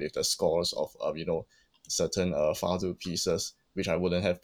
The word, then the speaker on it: fado: It's spelled as FADO, but pronounced as fado in Portuguese.